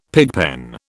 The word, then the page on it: pigpen: British English